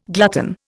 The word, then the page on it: glutton: Indian